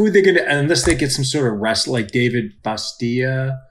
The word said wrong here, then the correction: Batista: Oh, Dave Batista. Baptista. – Baptista. Or, yeah. Batista. Neither of you guys Batista. Are saying it right.